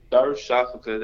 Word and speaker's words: Darüşşafaka: You know, last year I was in Darüşşafaka, that's how you pronounce the team, and I was in Istanbul, Turkey.